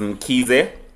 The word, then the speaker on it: Mkize: My surname is Mkize, but it's spelled M-K-I-Z-E.